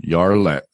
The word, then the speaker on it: Jarlet: And do they pronounce the J in Jarlet, or is it Yar? – Yar. – Okay. – Yeah, Yarlet.